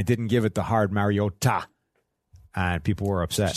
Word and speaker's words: Mariota: It is Mariota. And if you don't say it like that, you're going to be in trouble.